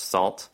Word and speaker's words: NaCl: So one example is DJB's NACL library, pronounced SALT.